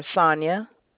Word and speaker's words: Sonya: Sure, Sonia. – Sonia, and how do you spell that? F-A-N-Y? – It's S-O-N-Y-A. – S-O-N-Y-A.